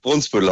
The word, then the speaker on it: Brunsbüttel: Brunsbüttel heißt es. Wird in den Medien auch falsch gesagt. – Brunsbüttel. Ne? Also wir merken uns das für alle Zeiten. Es heißt Brunsbüttel und nicht Brunsbüttel.